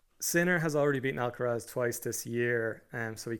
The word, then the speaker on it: Alcaraz: Pick him up on the pronunciation. Now, he gave us a lesson earlier on in Club Brugge. – Brugge. Carlos Alcaraz. – I'm just trying to be cool. That's what all the Spaniards call him.